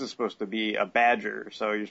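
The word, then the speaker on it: B'dg: It's B-apostrophe-D-G. – I think it's supposed to be like Badger.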